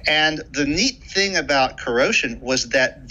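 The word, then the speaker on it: Kuro5hin: And around the year 2000, I started writing for a website called Corrosion, spelled K-U-R-O, digit 5, H-I-M. – Okay. – Meant to be pronounced like the word corrosion because the site's founder was named Rusty Foster.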